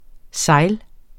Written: SEJLENES

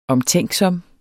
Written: omtænksom

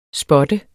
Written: spot

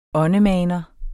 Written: åndemaner

